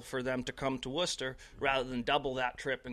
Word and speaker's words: Worcester: Yeah, and that's one of those kind of cool things about the Worcester Red Sox here is that, you know, again, you said it right. We are the Worcester Red Sox.